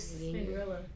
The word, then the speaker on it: Spinrilla: Spinderella. – Yeah. I mean, you know, folks. – Spinrilla. – Woo, take it back. – Spinrilla. That's how you say it, no? – Spinrilla.